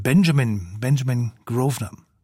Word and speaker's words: Benjamin Grosvenor: Und dazu haben Begleiter Nicola Benedetti, Geige, Chico Kanemason, Cello und den Pianisten habe ich vorhin falsch ausgesprochen. Er ist natürlich Engländer und heißt deswegen mit Vornamen Benjamin Grosvenor.